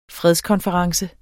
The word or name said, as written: fredskonference